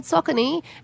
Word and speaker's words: Saucony: We were like, you mean Saucony? And they were like, no, it's called Saucony. And we thought that they just had their own name wrong. – Yeah. – But they don't. – It's Saucony. – Is Saucony. – Not Saucony. – Not Saucony, as I thought it was. It's Saucony, and we'll never get it wrong again.